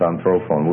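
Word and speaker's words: anthropon (ἀνθρώπων): Now one thing you're going to notice about a word like anthropos is the accent's going to shift. It starts out as anthropos, with the stress on the first syllable, and then when you get the genitive plural form that you have here, because the stress moves down the line and it's anthropon.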